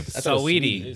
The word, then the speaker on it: Saweetie: Saweetie.